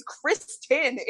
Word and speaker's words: Kristen: I'm like, it's not Christine. – Oh, it's phonetic. It's Kristen. It's phonetic.